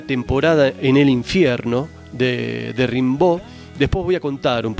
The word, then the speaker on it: Rimbaud: Creo que en francés es Rimbaud, pero yo muchas veces lo he escuchado como Rimbaud, así que bueno, como les guste, se escribe Rimbaud en realidad.